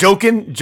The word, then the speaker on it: Joaquin: What did I say? – Oh, Joaquin Phoenix. – Johnny Phoenix. – Joaquin.